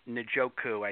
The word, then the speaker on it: Njoku: David Njoku, I think is how you say his last name.